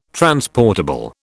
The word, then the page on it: transportable: British English